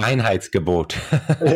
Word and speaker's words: Reinheitsgebot: Uh, I'm not great at pronunciations, but Reinheitsgebot. Um, Reinheits, and, uh, Reinheitsgebot.